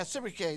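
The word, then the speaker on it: Sibbechai: Now here's a third killer, the killer of giants, Sabakiah. How do you want to pronounce it? How do you pronounce that? What is it? Yeah, Sibike. Yeah, you got it. That sounds right to me.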